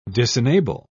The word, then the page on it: disenable: British English